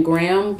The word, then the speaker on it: Graham: You can reach out to me on LinkedIn under my name, Allison Graham, and it's spelled A-L-L-Y-S-O-N. Last name Graham, like Graham Cracker, G-R-A-H-A-M.